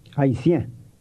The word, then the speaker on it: Haïtien: The word for Haitian is Haïtien. – It has a nasal sound to it.